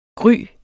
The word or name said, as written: gry